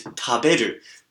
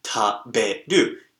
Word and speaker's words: taberu: So, for example, the verb to eat is taberu. Taberu. T-A-B-E-R-U.